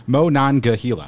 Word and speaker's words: Monongahela: We actually write it out phonetically. Monongahela. I did say it right.